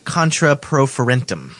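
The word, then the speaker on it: contra proferentem: Contra, how do you pronounce this? – I would say contra pro ferentum.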